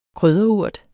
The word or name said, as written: krydderurt